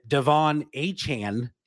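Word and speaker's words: Achane: That is, and the pronunciation has changed several times here on this young man's name, but Devon Achan. I think I got that right. – That's right. Yep.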